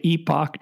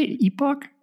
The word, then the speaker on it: epoch: How do you say that word? E-P-O-C-H. – I mean, I think epoch is right, epoch. But I think epic might also be okay.